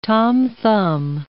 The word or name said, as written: Tom Thumb